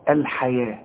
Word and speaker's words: الحياة: اشتروا الحياة، الحياة مكتوبة بالواو ولكن تنطق بالألف، ورسم لك ألف فوق الواو بيقول لك انطقها ألف كاملة، الحياة، لكن إذا كتبتها تكتبها بالواو.